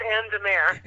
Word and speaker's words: Anne de Mare: Actually, it's Anne DeMar.